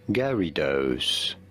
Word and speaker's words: Gyarados: So, the official pronunciation is as... Gyarados. Somewhat like Gyarados. – And Dose. Gyarados. Of course, there are two inexplicable facts in the spelling. The Y after the G and the A.